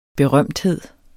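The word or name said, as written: BERØMTHED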